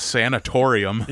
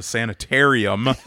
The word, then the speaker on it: sanitarium: In the vehicle, we're driving back, And every time we go by this place, Hollow mispronounces it sanatorium. – Yep. Yep. – It's on the way to Clintonville. There's an old mental hospital, sanitarium. But he calls it sanatorium every time.